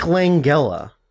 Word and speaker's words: Langella: Frank Langella. Or Langella, nice. Depending on how you want to pronounce it.